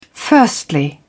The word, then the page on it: firstly: British English